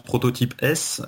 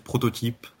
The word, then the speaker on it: prototypeS: Alors certains le prononcent prototype S, mais dans mon esprit, ça reste en fait prototype, simplement, au pluriel. Euh, et sachant que le S s'explique uniquement par cette majuscule, finalement.